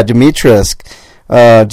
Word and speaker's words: Dimitrescu: Dimitrescu? Yeah, Dimitrescu.